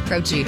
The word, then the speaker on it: Croce: Jim Croce, right? Yep. Yeah. – Is that how you pronounce that last name? – Jim Croce. – Croce.